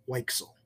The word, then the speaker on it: Weichsel: Manfred Weichsel.